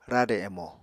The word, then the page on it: rade emo: Apatani